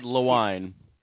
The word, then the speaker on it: Lewine: Yeah, it's Chad Lewine. – Lewine, just the way it looks.